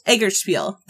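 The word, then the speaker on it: Eggertspiele: It's designed by Matt Leacock and published by Egertspiel? I can't pronounce it. – Egertspiel? – Egertspiel. That's it.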